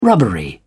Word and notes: rubbery: British English

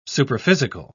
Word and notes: superphysical: British English

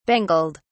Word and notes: bangled: British English

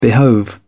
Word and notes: behove: British English

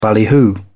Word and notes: ballyhoo: British English

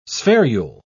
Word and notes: spherule: British English

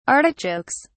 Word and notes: artichokes: British English

